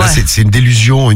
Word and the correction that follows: désillusion: une délusion, ça se dit? – Désillusion. – Désillusion, voilà. Oui. J'ai oublié une syllabe.